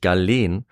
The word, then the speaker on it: Galen: Ja, nachdem äh, ich das eine Folge lang falsch ausgesprochen habe, äh, weiß ich es mittlerweile zum Glück, nämlich Galen.